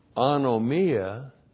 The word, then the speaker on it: anomia: Five, now there can be no wonderful government of any kind unless anomia is meseod.